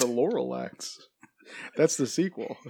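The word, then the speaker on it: Lorax: This comes to us from the director, Chris Renard, who did Despicable Me 1 and 2, as well as The Lorelats. Lorelats? – What did you say? The Lorelax? – Lorelats. – Well, you had a whole extra syllable in there.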